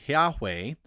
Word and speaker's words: Yahweh: You can do it with two syllables and say Yahweh, or with three syllables, Yehovah.